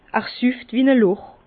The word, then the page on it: er süfft wie-n-a Loch: Bas-Rhin; Herrlisheim